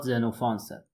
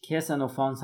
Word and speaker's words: زنوفانس: یکی از فیلسوفای جالب پیش از سقراط زنوفانسه. البته بسته به زبونی که مرجع قرارش میدیم اسمش کسنوفانس هم تلفظ میشه.